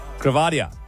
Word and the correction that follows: gravida: I have actually pronounced that one wrong now because you've ratted me. Gravida was what I was trying to say, which is the medical term for how many times a woman has been pregnant.